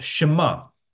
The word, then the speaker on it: Shema: This is a Hebrew word, and it's pronounced Shema, okay?